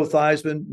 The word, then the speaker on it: Theismann: I said, Granny, they want to change the pronunciation of our last name from Theismann to Theismann. What are your thoughts? And she said, well, I tell you this. She says, actually, the correct pronunciation is Theismann, and they're closer than what we have now.